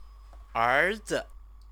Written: erzi

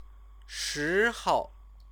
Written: shihou

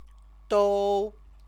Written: dou

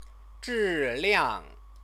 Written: zhiliang

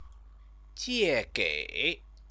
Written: jiegei

